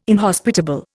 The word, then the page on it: inhospitable: Indian